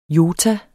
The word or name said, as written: jota